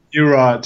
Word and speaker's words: Meurer: Just stick to a Mura. Just pronounce how you would pronounce it in English.